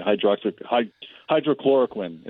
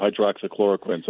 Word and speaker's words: hydroxychloroquine: I kept calling it hydrochloroquine instead of hydroxychloroquine.